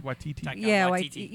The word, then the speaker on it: Waititi: Waititi.